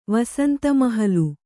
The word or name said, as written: ವಸಂತಮಹಲು